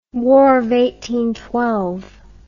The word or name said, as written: war of attrition